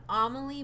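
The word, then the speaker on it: Amélie: Emile? I don't know how to pronounce Amelie? – This one. It's like Amelie, yeah.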